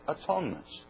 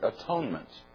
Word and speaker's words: atonement: But then they came to the last time the word reconciliation occurs, and they translated it atonement. Now, that's the way we pronounce that word, atonement. But it was pronounced earlier, at one-ment.